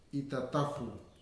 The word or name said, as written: itatafu